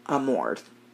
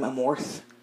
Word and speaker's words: Amorth: Um, and I was pronouncing his last name Amorth because that's how it's spelled. – Oh. Naturally, I was fucking wrong. – Uh, of course. – Yeah. It's actually pronounced Amort. Oh, okay. That H is silent.